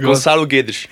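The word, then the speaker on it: Gonçalo Guedes: Goncalo Guedes. – I've worked long enough with Andre to know this one. Gonzalo Guedes.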